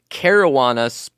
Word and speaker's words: Caruana: That was Luke Caruana, pronounced like marijuana, but with a CA instead.